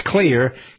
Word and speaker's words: Xlear: It's pronounced clear, spelled X-L-E-A-R.